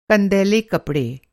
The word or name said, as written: कंदैला